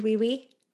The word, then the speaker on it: Oui We: It's spelled O-U-I, like yes in French, and then we, like you and me, we.